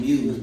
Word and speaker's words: M.E.W.S: M.E.W.S. Because I know y'all think it just Muse. No, M.E.W.S.